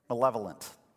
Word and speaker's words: malevolent: I mispronounced it last night. I failed at the exact same point. I talked to people in the back of the room. I'm like, how do you say that word? And they said malevolent.